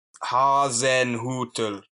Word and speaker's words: Hasenhüttl: Yeah, so it's not Hasenhüttl, as we've been pronouncing it. You actually pronounce it Hasenhüttl.